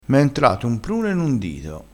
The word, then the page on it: prune: dialettale